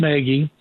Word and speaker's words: Nagy: I keep hearing as one of the guys, Matt Nagy, spelled Nagy, but pronounced Nagy, back with Kansas City, that Bagonzi was there forever, that he's a candidate.